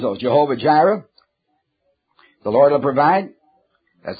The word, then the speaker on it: Jehovah Jireh: But now all of those are found in various scriptures, such as Jehovah Jireh. We pronounce it Jehovah Yireh, what it really is pronounced.